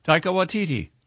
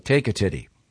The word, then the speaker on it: Taika Waititi: And pronounce it again. Taika. – Taika Waititi. – Okay. I thought it was like take a titty.